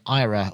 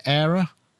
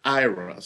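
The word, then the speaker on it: AIRA: And she says, we don't pronounce it like that anymore. I'm like, what anymore? It's not even out yet. – Did she tell you? – We've pronounced it Ira. So you pronounced it correctly. Ira.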